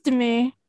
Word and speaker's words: Demi: So if you see Demi Michelle and the butterfly, that's me. – And I don't know if I've said it wrong the whole time. Is it Demi or Demi? – It's Demi. Yeah, Demi.